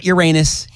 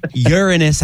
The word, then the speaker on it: Uranus: It's Uranus. Not Uranus, bunch of immature individuals. Uranus, that's how you're supposed to pronounce it.